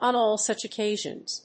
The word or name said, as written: on all such occasions